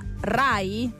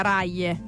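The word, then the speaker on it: Raye: Allora, credo sia Rai. – Rai, perché Rai è scritto Raie con la Y. – Però non credo si dica Raie. – Come Rai 1, Rai 2, Rai 3.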